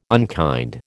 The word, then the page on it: unkind: American English